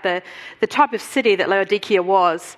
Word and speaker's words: Laodicea: That's actually how they say it in Turkey, Laodicea, because they don't have a soft C in the Turkish language.